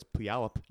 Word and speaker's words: Puyallup: Well, I know it's Puyallup now, okay.